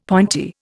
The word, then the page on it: pointy: Indian